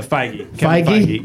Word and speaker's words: Feige: Kevin Feige. – Well, maybe Feige? Is that how you pronounce it? Feige? – Yeah, Feige. Yeah.